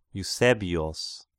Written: Eusebios

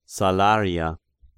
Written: Salaria

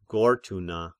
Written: Gortyna

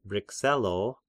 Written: Brescello

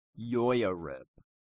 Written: Joiarib

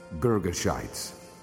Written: Girgashites

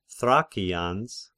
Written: Thrakians

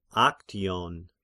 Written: Aktion

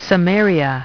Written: Samaria

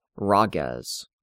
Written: Rhages

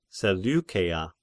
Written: Seleukeia

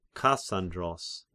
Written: Kassandros

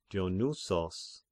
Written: Dionysos

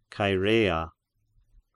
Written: Chaerea

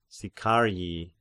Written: Sicarii

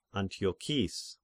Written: Antiochis

